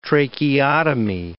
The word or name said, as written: tracheotomy